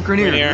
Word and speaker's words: Granirer: Yeah, Granirer.